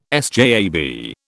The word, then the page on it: SJAB: British English